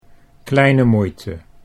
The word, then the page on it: kleine moeite: Dutch